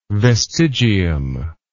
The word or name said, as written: vestigium